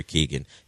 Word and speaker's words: Keoghan: I always butcher his last name. – Keegan? – Is it Keegan? Okay. I was going to call him Sabrina Keoghan? Carpenter's ex. But but yes, Barry Keegan is in it.